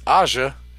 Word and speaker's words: Aja: Bullshit, Asia. Motherfucker. It's Aja, and that's how I'm always going to pronounce it.